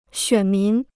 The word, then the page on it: 选民: xuǎn mín